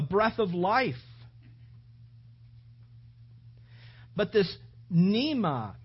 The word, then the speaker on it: pneuma: The word Spirit there that is spoken over 20 times in this passage is the word, the Greek word, pneuma, which is spelled P-N-E-U-M-A. But it's pronounced pneuma, which literally means to breathe.